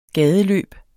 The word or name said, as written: gadeløb